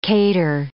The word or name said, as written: cater